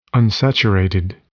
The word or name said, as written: unsaturated